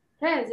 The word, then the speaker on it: Fez: Yeah, and I wanted to do Marrakesh, but I wanted to do Fez. That's how it's pronounced? – Fez, yeah. – Fez, I wanted to do Fez, and everyone says Casablanca, so Casablanca.